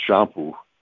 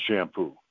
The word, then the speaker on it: Champoux: You know, my name is pronounced Champoux, but Champ is not something that's pronounced very well by New Englanders with their nasal.